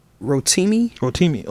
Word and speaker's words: Rotimi: Rotimi.